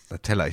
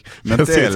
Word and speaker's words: Natella: Natella. Sagen, ne? Natella. Genau. Die Nutella nur mit A quasi. Und U, also ohne U mit A. Genau.